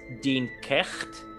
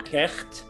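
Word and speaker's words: Dian Cécht: I think it's pronounced Dinkecht. – Ooh, nice. – Dinkecht. D-I-A-N-C-E-C-H-T. I'm pretty sure it's Dinkecht.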